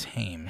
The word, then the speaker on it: Haim: Is it Haim or Haim? But the same... sisters. – I believe it's Haim. – Haim.